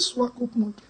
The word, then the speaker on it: Swakopmund: He said, Swakopmund.